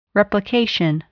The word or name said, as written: replication